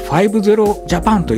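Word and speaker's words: 00000JAPAN: 05 つとジャパンと書いて 50 ジャパンと読むんですけども、面倒な設定やパスワードがいらない Wi-Fi なので誰でも簡単に使えるようになります。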